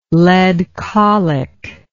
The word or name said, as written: lead colic